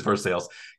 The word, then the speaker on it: Versailles: Yes, it is Versailles.